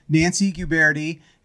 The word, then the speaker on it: Nancy Guberti: We have a very special guest, Nancy Guberti.